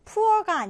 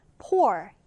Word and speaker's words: pour: poor가 아니라 pour 이렇게 발음해 주는데 자, 이건 액체 같은 것을 붓다 또는 음료를 따르다라는 뜻입니다.